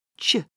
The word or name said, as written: ch